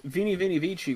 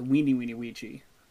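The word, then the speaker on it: veni vidi vici: Well, in Latin, which is slowly becoming one of my favorite languages for some god-awful reason, I don't know why, like, literally, Vini Vini Vici was not pronounced that way. It was Weeny Weeny Weechi.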